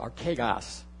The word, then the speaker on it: archegos: It's pronounced archegos.